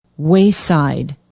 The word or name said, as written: wayside